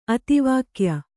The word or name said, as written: ಅತಿವಾಕ್ಯ